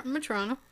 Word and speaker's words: Metrano: I think that's how you say it. Yeah, looks like Metrano. – Is that what you say? Yeah, Metrano.